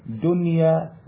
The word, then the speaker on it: دنيا: أمال هنا ما دخلت النون على الواو وعلى الياء قنوان دنيا صنوان. طيب هنا الكلمات ديت دونا عن بقية الكلمات القرآنية والأحكام دي لوحدها. فاش أقول ديا ولا قوان ولا صوان، لازم تنطق هكذا بالإظهار، دنيا وقنوان وصنوان.